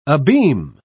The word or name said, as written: abeam